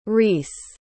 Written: rees

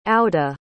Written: auda

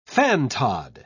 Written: fantod

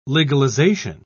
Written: legalization

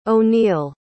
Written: O'Neill

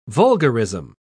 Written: vulgarism

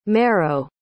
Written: mero

